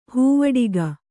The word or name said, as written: ಹೂವಡಿಗ